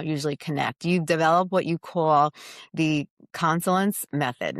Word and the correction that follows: coalescence: Now, am I saying that right? Consolence? – Coalescence. – Like it's marrying, I was close.